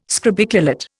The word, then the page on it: scrobiculate: Indian